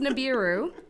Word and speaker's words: Nibiru: I feel like I should pronounce it Nibiru now. – No, it's Nibiru.